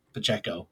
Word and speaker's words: Pacheco: I won't ever mispronounce his name again, Pacheco.